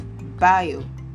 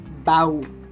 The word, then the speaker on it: bio: So to pronounce this word correctly, you have to say I-O. So that is bio, bio. You aren't to say bow. Do not say bow when you're pronouncing this word. Say bio.